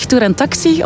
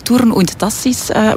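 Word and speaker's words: Thurn und Taxis: Ik zelf zeg Tour en Taxi, omdat ik het gemakkelijker uitspreken vind. Maar eigenlijk in het Nederlands zou het van Tourne und Tassis moeten zijn. Fransen zeggen dan eerder Tour en Taxi.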